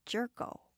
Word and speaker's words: Jerko: Well, it's Dr. J-E-R-K-O. – Oh, Dr. Jerko. – No, it's actually, he's a little sensitive. It is pronounced Dr. Jerko.